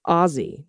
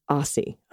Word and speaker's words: Aussie: It's pronounced Ozzy, not Aussie.